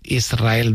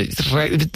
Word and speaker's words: Israel: Y de diminutivo, de chiquitito, Israel, el que ella quería, porque Israel creo que le sonaba muy grande, ¿no? Israel. ¿No? – Y la S con la R es difícil de pronunciar.